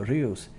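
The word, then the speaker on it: Reus: I said, hey, I'm flying into Rios.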